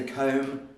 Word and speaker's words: comb: The B is silent because I don't say the B. There are some dialects where they say B, so they like comb, but there's that's only in some very specific dialects.